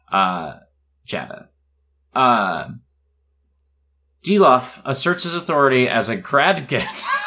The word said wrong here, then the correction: Gragdet: Gragdet, thank you.